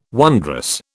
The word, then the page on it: wondrous: British English